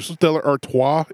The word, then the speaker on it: Stella Artois: Stella Artois, is that how it's said? – Yeah.